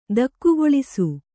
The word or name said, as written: ದೆಖ್ಖಾಳಿಸು